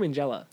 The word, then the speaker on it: Minghella: Is it or Minghella? I say the hard G.